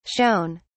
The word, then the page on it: shewn: British English